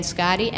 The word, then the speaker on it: Scotti: My name is Roseanne Scotti, and I am the director of Drug Policy Alliance, New Jersey.